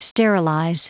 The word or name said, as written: sterilize